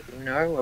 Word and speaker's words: Uno: You know, I've got one card left, but Uno apparently is the proper way to pronounce it.